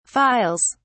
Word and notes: files: British English

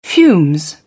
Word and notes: fumes: British English

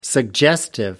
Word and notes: suggestive: American English

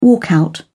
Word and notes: walkout: British English

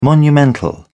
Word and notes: monumental: British English

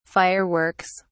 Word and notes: fire works: British English

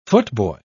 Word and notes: footboy: British English

